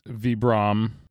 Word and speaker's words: Vibram: But Vibram or Vibram, however you pronounce it, I always forget. – It's Vibram. – Okay, Vibram.